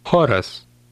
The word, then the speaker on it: Choras: The letter combination CH in Lithuanian is pronounced like the English letter H, but with more breath. Listen and repeat. – Choras.